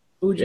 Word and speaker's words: Fujin: Is that Fujin. His name? – Fujin. Yeah. You better respect the God of Wind.